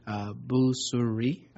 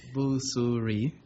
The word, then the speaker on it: Busuri: Busuri. I've been saying Missouri, but with a B. But somebody said no, that's Mus, but it's not Boo. So Busuri is my Councilmember Busuri is my name.